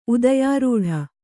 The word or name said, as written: ಉದಯಾರೂಢ